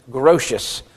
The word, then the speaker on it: Grotius: It also is correctly pronounced Grotius, from which we get the governmental view of the atonement, or using his name, the Grotian view of the atonement.